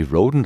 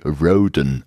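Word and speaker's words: Rhodan: Ich bin noch gar nicht ganz durch, aber bevor ich es vergesse, wollte ich gleich mal ergänzend zur Aussprache des Namens unseres Helden tätig werden. Wie Alex richtig sagte, gibt im Fan, gibt, gilt im Fandom jeder wie er mag. Seit seinem großen Prequel-Roman Perry Roden, das größte Abenteuer von Andreas Eschbach, ist die englische Aussprache mehr oder weniger kanonisiert worden denn der deutsche Zweig von Perrys Vorfahren hörte auf den Namen Roden, der dann bei der Umsiedlung nach Amerika eingeenglischt wurde.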